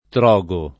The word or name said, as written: Trogo